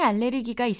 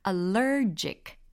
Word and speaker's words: allergic: 그들은 고양이 알레르기가 있습니다. They're allergic to cats. 딩동댕. They're allergic to cats. 오, 발음도 좋아지셨어요. 옛날에는 알레르기, 알레르기 이랬는데, 이제는 형용사로 allergic 이런 발음도 우리가 잘 할 수가 있는 능력이 생긴 거죠.